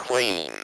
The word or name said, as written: clean